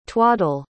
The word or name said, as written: twaddell